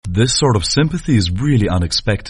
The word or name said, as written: sympathy